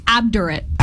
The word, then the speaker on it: obdurate: Obdurate. – Obdurate. Is that like obdurate? O-B-D-U-R-A-T-E? – My phonetics say A-H-B. Obdurate. – Okay. – But it is not phonetically. It is O-B-D.